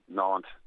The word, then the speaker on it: Nantes: N-A-N-T-E-S, Nantes is the way they pronounce it.